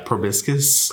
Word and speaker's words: proboscis: I don't know if I'm saying that right. – Proboscis. – Proboscis. Yeah, that sounds better.